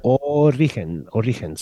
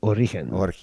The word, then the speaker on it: Origen: Origen de la Mascara. – Origen. So just think origin, but soft G. – Origen.